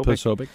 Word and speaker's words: Posobiec: Jack Posobiec. I think that's how you say his name, Posobiec. – Posobiec.